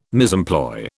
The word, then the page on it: misemploy: British English